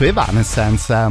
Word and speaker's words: Evanescence: Ci siamo più o meno messi d'accordo su Evanescence, ma insomma voi fate riferimento per queste cose sempre e soltanto alla nostra Paulonia.